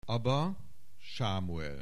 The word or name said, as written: Aba Samuel